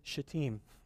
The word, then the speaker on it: Shittim: If you've ever wondered how to pronounce that, it's Shittim.